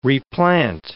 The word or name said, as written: replant